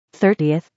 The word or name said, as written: 30th